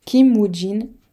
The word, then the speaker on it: Kim Woojin: Le 28 août, il y aura le retour des signatures et des One Le lendemain, celui des H1K, des X-In, de Kiwujin, Kim Woojin, je l'ai mal prononcé, et des Pentagon, et c'est un retour japonais.